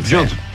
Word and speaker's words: Jude: El fenómeno del Real Madrid, de Jude Bellingham, ¿se pronuncia así? – Sí, Jude.